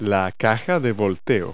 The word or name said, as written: dump body